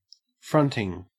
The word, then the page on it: fronting: Australian English